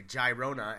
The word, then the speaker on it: Girona: Girona!